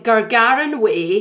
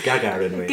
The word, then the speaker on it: Gagarin Way: And I was going to suggest that we all club together and we buy a council house on Gargarin Way in Lumphinnons. – Pronounce it Gagarin Way. – Gagarin Way. Gagarin. Is it sorry? – Like sorry. – Yuri Gagarin.